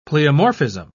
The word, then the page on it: pleomorphism: British English